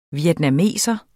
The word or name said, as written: vietnameser